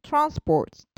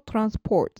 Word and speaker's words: transport: 例えば、減少という名詞でしたら decrease なんですけれども、減るという動詞の場合は decrease になるですとか、輸送という名詞でしたら transport と、アクセントが前に行きますけれども、輸送するという動詞になると transport になって後ろに移動するんですね。